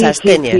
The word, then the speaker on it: astenia: Es astenia. – Sí, bueno, yo creo, sí, astenia primaveral, que siempre tengo que decir, se pronuncia así.